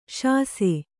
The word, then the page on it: ಸಾಸ: sāsa